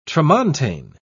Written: tramontane

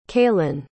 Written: calen

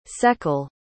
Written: secle

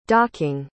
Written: docking

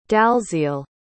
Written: dalziel

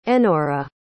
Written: enora

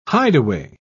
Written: hideaway

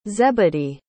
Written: zebedee

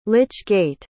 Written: lych-gate

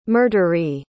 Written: murderee

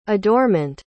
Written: adorement

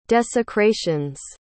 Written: desecrations

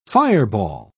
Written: fireball

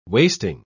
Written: wasting

